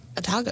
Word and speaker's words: Otago: Otago.